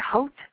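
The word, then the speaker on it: Haute: Hote, is that how you say it? Hote? Hote and bothered. – Hote and bothered. Yes. – Hote and bothered. You just want to say it with an English accent, which I suck at.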